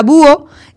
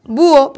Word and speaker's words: búho: Pues la palabra búho lleva tilde donde en la U. Entonces yo digo búho, porque si no diría búho.